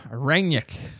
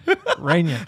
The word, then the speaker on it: Rangnick: Rangnick. Rangnick.